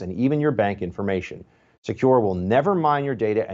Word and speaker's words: Sekur: That's why you need a new privacy and cybersecurity tool called Secure. It's spelled S-E-K-U-R.